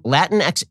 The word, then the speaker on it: Latinx: So she spoke at the, quote, Latinx Inclusion Luncheon in San Antonio on Monday. I pronounce it that way because that is how it's spelled. It is spelled L-A-T-I-N-X, Latinx, Latinx, Inclusion. I-N-C-L-U, capital X-I-O-N, luncheon in San Antonio.